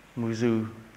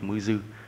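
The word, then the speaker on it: Mujū: Tiếng Nhật á, viết Mư Dư, chữ U đọc là Ư nha, tiếng Nhật Mư Dư.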